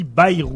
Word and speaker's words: Bayrou: D'abord, l'autre, ça se dit pas Bérou, ça se dit Bayrou. – Bayrou.